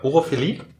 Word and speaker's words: Urophilie: Und zwar geht's um, warte, Urophilie. Oder? Habe ich es richtig ausgesprochen? Ja, Urophilie.